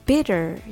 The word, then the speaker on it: bitter: bitter と deter の違いは 2 つの音節のうちどちらにアクセントがあるかです bitter にはアクセントが最初の音節にあります deter にはアクセントが後の音節にありますアクセントの位置によってアルファベットの t の音が変化します。